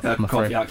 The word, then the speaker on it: Krychowiak: Krychowiak, yeah, I know. Krychowiak.